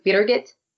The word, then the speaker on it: Birgit: But what it is the way that she taught me to say it is basically you say beer gut, but instead of saying gut, you say it. And you have to put an accent on the R and the G combination. So it's Birgit.